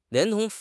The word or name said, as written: 连同